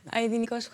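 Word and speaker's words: Aïdinikos: Alors, je vais sûrement très mal le prononcer. La chanson s'intitule Aïdnikos euh, Choros. C'est comme ça qu'on le dit Aïdnikos Choros.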